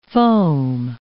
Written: foam

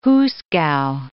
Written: hoosegow

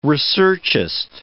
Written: researchist

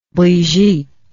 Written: brigitte

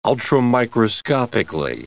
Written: ultramicroscopically

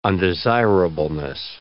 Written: undesirableness

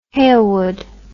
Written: Hailwood

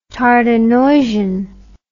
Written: Tardenoisian

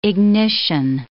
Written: ignition

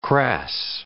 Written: crass

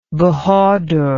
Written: bahadur

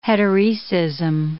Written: heteroecism

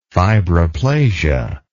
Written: fibroplastic

